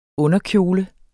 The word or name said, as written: underkjole